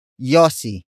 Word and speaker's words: yosi: Todits, which is spelled as T-O-D-I-T-S. Our last word for this lesson, yossi. Yossi. Yossi. Yossi is spelled as Y-O-S-I.